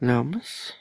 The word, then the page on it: laumes: Lwaitel